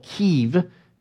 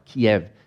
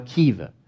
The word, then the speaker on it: Kyiv: They have baptisms every Sunday in, in Kiev, and, uh, which they now call Kyiv because that's Ukrainian pronunciation. They're not pronouncing anything with a, in the Russian way anymore, Kiev. So it's now Kyiv.